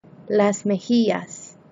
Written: las mejillas

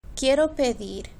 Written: quiero pedir

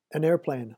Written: an airplane

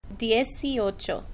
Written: dieziocho

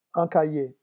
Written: un cahier

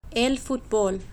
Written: el futbol

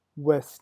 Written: ouest

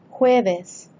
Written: jueves

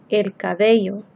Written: el cabello

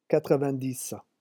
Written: quatre vingt dix

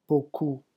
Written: beaucoup

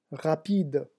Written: rapide

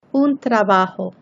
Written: un trabajo

